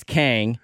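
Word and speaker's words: Khang: But because it's K-H-A-N-G. – Yeah, the H throws people off.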